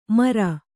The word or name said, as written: ಮೆರೆ